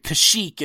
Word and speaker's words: Kashyyyk: But anyways, they're headed to Kashyyyk. That's how I pronounce it, right? – Kashyyyk. – Yeah, they pronounce it weird. Kashyyyk is the official pronunciation.